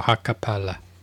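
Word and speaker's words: hakkaa päälle: It rhymes with acapella. – Acapella. – Yeah, so Hakapale.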